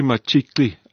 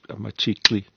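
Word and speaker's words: Matshiqi: Aubrey Machikli, I'm trying very hard to pronounce that. You got that right, spot on. – Machikli, that's the right one.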